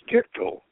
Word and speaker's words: Gichtel: And as I said, you can look at Gitchell's Gichtel. I keep mispronouncing it Gitchell. It's Gichtel.